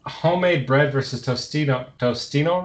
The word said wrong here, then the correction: Totino's: rolls. Is that, am I saying that right? – Totino's pizza rolls.